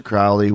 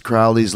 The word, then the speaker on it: Crowley: He said, Ozzy mispronounces Crowley's last name. It is, in fact, pronounced with the first syllable sounding like crow.